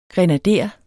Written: grenader

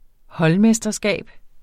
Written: holdmesterskab

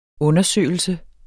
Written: undersøgelse

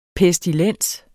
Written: PESTILENSERS

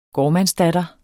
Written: gårdmandsdatter

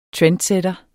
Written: trendsætter

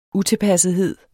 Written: utilpassethed

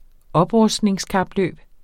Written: oprustning

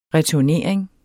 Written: returnering

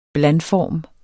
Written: blandform

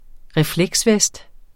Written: refleksvest

